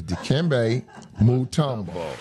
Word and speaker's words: Dikembe Mutombo: And not to get off the subject, but let's get a prayer out for McKimbe Natumbo. – Yeah. First of all, say his name right. His name is Dikembe Mutombo.